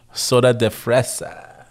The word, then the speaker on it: Soda de Fresa: Soda de Fresa.